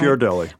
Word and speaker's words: Ghirardelli: She brought us this unbelievably fantastic fudge pie, like a chocolate pie with Ghirardelli chocolate in it. Did I pronounce that right? – Ghirardelli.